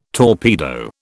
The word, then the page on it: torpedo: British English